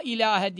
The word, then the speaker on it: ilahe: La ilahe diye la ağız açılarak ve uzatılarak. İlahedeki he'ler gözlü he olarak çıkartılacak. Boğaza takılmayacak. Hâhı gibi yapılmayacak. İlahe şeklinde göbekten gelecek. Boğazdan takılmadan geçecek. Gözlü ha dediğimiz harf olarak telaffuzu doğru yapılacak.